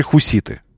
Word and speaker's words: хуситы: А мы в открытую, мы люди искренние, и поэтому мы аплодируем новой заварухе, в которую втянуты теперь хуситы. Произносите, пожалуйста, правильно. Хуситы, вот так это произносится.